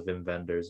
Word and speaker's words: Wim Wenders: Another connection we have to, I'm gonna make sure I don't say Wim Wenders, is that how you actually say his name? I know it gets, yeah, it's German, the W's are V's, yeah. So Wim Wenders.